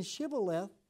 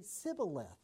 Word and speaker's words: Shibboleth: Well, because the men of Ephraim could not pronounce the SH sound. They couldn't do the sh sound. So instead of saying Shibboleth, they would just say Sibboleth without the H.